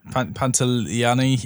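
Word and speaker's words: Pantoliano: And Joe Pantaloni. – Pantaliani. Yeah. Never know how to pronounce his surname.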